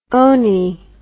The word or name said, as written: ony